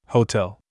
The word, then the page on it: apron: English